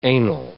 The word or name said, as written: anal